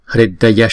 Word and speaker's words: Hridaya: Sarvatthagata Hridaya Shuddhe Swaha, chữ tha là hai nhịp, Sarvatthagata Hridaya Hridaya, chữ R có dấu nặng này là đọc là Ri hay là Ru hay là R, tùy theo vùng miền ở Ấn Độ thì chúng ta đọc ở miền Bắc Ấn Độ là đọc là Ri, thì cái chữ này ghép lại chúng ta đọc là Hridaya Shuddhe Shuddhe,